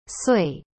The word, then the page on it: 岁: sui